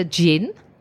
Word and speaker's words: gin: And it turns out there is a djinn that is called Akko. – Djinn, for people that can't understand her accent, djinn, like the liqueur, the liquor, djinn.